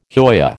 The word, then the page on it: hoya: American English